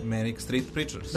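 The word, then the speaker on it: Manic Street Preachers: Ja sam grešio za pesmu koja je malo čas išla, a grupa je, kako sam ja to čitao i izgovarao, Many Streets priča. Jel znaš ko je? – Many Street Preachers.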